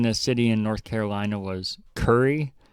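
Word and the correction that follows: Cary: And it's totally pronounced Carry.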